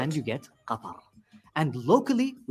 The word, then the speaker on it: Qatar: Pretty much every consonant in the word is not found in English. Qatar. – So just to recap, the official pronunciation of my country is Qatar. Qatar. – Qatar. – So go ahead and close your throat. – It's very guttural.